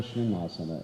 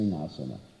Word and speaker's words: Singhasana: It's not a Shringasana, it's a Singhasana. Most devotees pronounce it improperly. They say Shringasana. It's Singhasana, which means a lion throne.